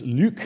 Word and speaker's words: Luc: The guy's name is Luc, if you want to get the French pronunciation of Luc. Luc Ferry.